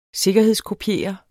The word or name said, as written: sikkerhedskopiere